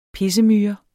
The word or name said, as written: pissemyre